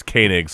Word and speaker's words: Koenig: I said Koenig. Apparently it's Koenig, so I'm going to blame my southern drawl for the mispronunciation.